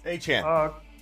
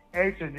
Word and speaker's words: Achane: Justin Fields, Bijan, Diachane. I can't say his name. – Achane. – Achane, yeah, okay.